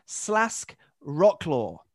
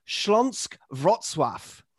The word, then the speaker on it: Śląsk Wrocław: Can I nominate of the Polish Ekstraklasa, and I'm going to say it as written phonetically, Śląsk Wrocław.